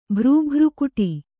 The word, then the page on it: ಭರಾಭರಿಗೊಡು: kannada